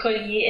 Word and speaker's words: collier: So in this case, it is collier, and we do pronounce the double L.